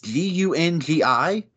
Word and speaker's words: Gungi: It's Gungi.